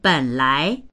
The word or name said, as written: ben3lai2